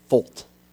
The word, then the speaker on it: Feucht: When you say Folt, it's actually spelled F-E-U-C-H-T. Yeah, that's how you pronounce it. Folt.